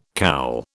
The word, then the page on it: cowl: British English